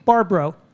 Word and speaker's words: Barbara: Barbara, just two syllables, Barbara. – Yeah, right. – I've been reminded by my friend Roscoe to say it that way always.